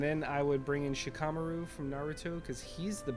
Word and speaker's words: Shikamaru: So the him, and then I would take from Shikamaru is the Japanese pronunciation.